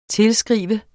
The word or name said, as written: tilskrive